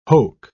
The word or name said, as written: hoke